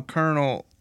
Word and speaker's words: colonel: There's no R in Colonel, so. – C-O-L-O. – It sounds like it, but there is. – C-O-L. O-N-E-L, Colonel.